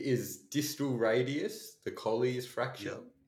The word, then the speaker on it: Colles: B, the distal radius, also known as the Colles fracture. – Yeah. – Am I saying that right? – Yeah, you're right. Yeah, Colles fracture.